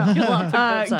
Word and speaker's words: Gallipolis: Galapagos. – No. Gallipolis. It's Gallipolis. So Gallipolis, Ohio.